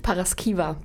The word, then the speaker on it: Paraskiva: Wie wirst du ausgesprochen? Das frage ich jetzt mindestens fünfmal. – Paraskiva. – Paraskiva, wo kommt denn der Name überhaupt her? – Der kommt aus Rumänien.